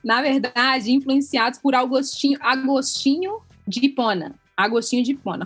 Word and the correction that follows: Agostinho de Hipona: Falei certo, meninas? Agostinho de Hipona.